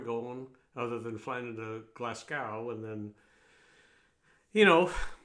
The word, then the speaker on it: Glasgow: It's not Glasgow, it's Glasgow, if I'm saying it right. Just like it's not Belfast, it's Belfast.